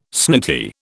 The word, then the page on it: snooty: British English